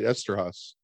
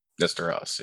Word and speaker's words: Eszterhas: Es Ezerhaus or whatever. Oh, right. Eszterhas. Eszterhas, yeah.